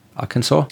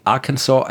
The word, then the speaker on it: Arkansas: Ja, in einem, einer Zeitschrift, im Journal of the Arkansas, wie spricht man Arkansas aus? Arkansas? – Ja, so. – Ja. ja. Journal of the Arkansas Academy of Science.